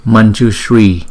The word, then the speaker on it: Manjushri: Kính thưa các bạn, Văn Thù Sư Lợi Bồ Tát có tên phạn là Manjushri hay Mamjushri, vì mẫu tử M có dấu nặng này là Anuswara trong tiếng Phạn và theo liền sau đó là chữ J. Được phát âm ra từ vùng palato nên chữ M dấu nặng này phải được chuyển thành chữ nhờ khi phát âm, cho nên chữ này chúng ta đọc là Manjushri.